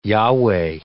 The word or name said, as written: Yahweh